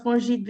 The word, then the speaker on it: FONGIT: So at FONGIT, it's pronounced FONGIT because of the French language, of course.